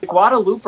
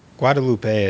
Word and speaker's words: Guadeloupe: Is it Guadeloupe or Guadalupe? – I think it's Guadeloupe. – We have no preparation on our pronunciations there. – Guadalupe is, I think, something else. Anyways.